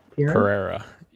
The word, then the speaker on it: Pereira: Attack of the Show is back with host Kevin Pereira. Did I say that right? – Pereira. Yeah. – Pereira.